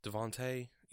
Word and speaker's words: Davante: DeAndre Hopkins of the Cardinals, and uh, Adam Devaney, if I'm pronouncing that right. Davante, yeah, Davante Adams.